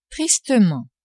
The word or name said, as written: tristement